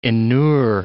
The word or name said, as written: inure